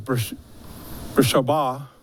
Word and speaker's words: Beersheba: If you want to pronounce it correctly, I do believe it's Beersheba.